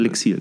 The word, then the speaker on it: Lixil: Äh, Lixil, ist es richtig ausgesprochen? Oder? Lixil, genau. Lixil.